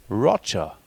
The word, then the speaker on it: Roger: Und deswegen spricht man seinen Namen auch Roger aus und nicht so wie in der französischen Schweiz, weil er nämlich in der Nähe von Basel aufgewachsen ist, Roger.